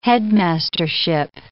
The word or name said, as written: headmastership